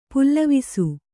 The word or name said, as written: ಪ್ಲವಿಸು